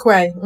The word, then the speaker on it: Quay: A, e, a powiedz mi, e, bo no jak z tego co widzę, z, z tobą wcześniej jeszcze rozmawiałem, wspomniałaś właśnie tych braci Quen, Queen, jak się ich wymawia? – Mhm, Quay, mhm. – Quay. – Um, Bra bracia Quay, tak, tak, tak.